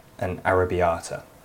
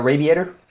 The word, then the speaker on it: arrabbiata: I used to work in a pub where we had a pizza called an Arrabbiata. And this American guy came and went, yeah, I'll take the radiator.